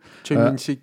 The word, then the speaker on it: Choi Min-sik: Il se trouve qu'en fait, elle tombe sur Choi Min-sik, qui est... c'est ça ouais, je dis bien ça. Je ne sais pas le prononcer. – Choi Min-sik, quoi.